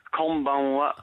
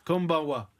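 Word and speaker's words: Konbanwa (こんばんは): Konbanwa. – Konbanwa.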